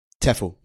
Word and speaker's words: TEFL: You started a business to train other teachers of English as a foreign language, which is, do you pronounce the acronym TEFL? – TEFL, yeah, Teaching as a Foreign Language.